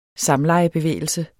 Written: samlejebevægelse

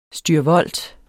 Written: STYRVOLT

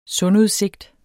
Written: sundudsigt

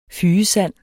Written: fygesand

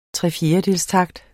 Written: trefjerdedelstakt

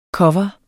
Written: Coveret

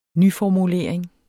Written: nyformulering

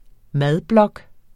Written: madblog

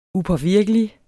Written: upåvirkelig

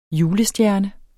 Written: julestjerne

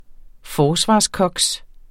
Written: forsvarskoks

